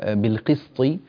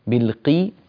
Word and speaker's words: بالقسط: في كلمة بالقسط بالقي اكسر القاف كسرا كاملا، ولا تقول بالقي قي قي، نتنبه إلى هذا، وهذا يسمى إتمام الحركات.